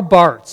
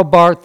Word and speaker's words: Barth: Do you remember when, you probably wouldn't, Karl Barth, spelled B-A-R-T-H with a silent H, Karl Barth,